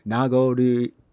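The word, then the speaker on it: Nagoriyuki: I can never pronounce his name. Nagoryuki. It's just long. It has so many syllables in it. Nagoryuki. There we go.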